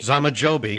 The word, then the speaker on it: Zamajobi: Zamajobi. – Zamajobi. – Yeah, it really pronounces pretty much like it looks.